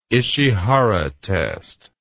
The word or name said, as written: Ishihara test